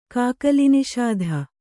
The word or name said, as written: ಕಾಕಲಿನಿಷಾಧ